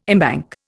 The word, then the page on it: embank: Indian